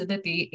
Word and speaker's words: Aditi: because my name is Aditi, A-D-I-T-I, but I always introduce myself as Aditi because like nobody that I come in contact with can actually pronounce my name.